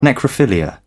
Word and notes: necrophilia: British English

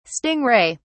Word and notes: sting ray: British English

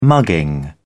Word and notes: mugging: British English

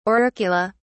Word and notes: auricula: British English

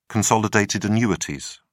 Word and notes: consolidated annuities: British English

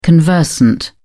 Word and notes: conversant: British English

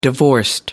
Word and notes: divorced: American English

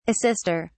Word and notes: assister: British English

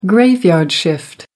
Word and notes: graveyard shift: American English

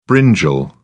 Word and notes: brinjal: British English

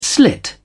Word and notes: slit: British English

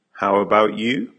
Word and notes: how about you: American English

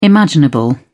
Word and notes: imaginable: British English

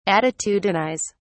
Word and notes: attitudinise: British English

